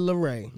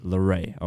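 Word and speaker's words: LAROI: The kid, is this how you pronounce it? The kid, Larray. – The kid, Larray. – Larray, okay. Is getting accused of using Juice WRLD's name too much right now.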